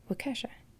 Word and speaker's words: Waukesha: I don't know if I'm saying it right. Waukesha. Waukesha. – Sounds right.